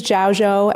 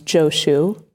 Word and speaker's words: Zhaozhou: So this is Zhaozhou and Nanquan. You might recognize their Japanese pronunciation of their names, Joshu, right? As in Joshu's Mu, and Nansen, like Nansen kills the cat.